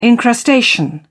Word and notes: encrustation: British English

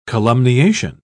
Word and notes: columniation: British English

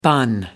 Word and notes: bun: American English

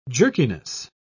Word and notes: jerkiness: British English

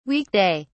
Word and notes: week day: British English

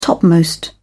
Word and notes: topmost: British English

